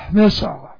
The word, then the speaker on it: miseo: Alright, M-I-S-E, long O. Miseo.